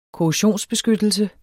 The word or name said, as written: korrosionsbeskyttelse